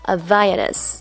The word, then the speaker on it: Havaianas: 这个字呢是葡萄牙文，跟英文发音规则有点不一样。这个词组以下有四个音节，重音在第三音节啊，H 是不发音的，字母 A 发啊的音，E 发 e，所以念起来就像是中文的 Havaianas。